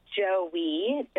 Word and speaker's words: Joey: It's Joey. Joey, like the baby kangaroo.